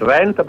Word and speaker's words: Venta: Uh, nevis venta, bet venta.